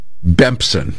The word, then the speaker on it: Bempson: Bempson rhymes with Simpson, as in the Simpsons.